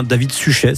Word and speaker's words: Suchet: Et ben du coup, on enchaîne avec le 2 mai 1946 et la naissance d'un comédien britannique qui s'appelle David Suchet, ou bien David Suchet si on veut le prononcer à la française.